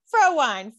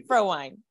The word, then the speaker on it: Frowine: They didn't find it funny when they would mispronounce my name, but this candidate's name is Frowine. Fro like the hair, wine like the drink. Emma Frowine.